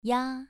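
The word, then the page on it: ya: tone 2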